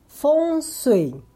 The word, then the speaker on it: Feng Shui: Em mandarim se pronuncia Feng Shui.